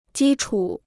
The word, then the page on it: 基础: jī chǔ